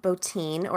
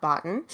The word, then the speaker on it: Bottin: So I want to talk about Rob Bottin, or as I've been mispronouncing it my entire life, Rob Bottin.